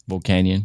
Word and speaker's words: Volcanion: Volcanion.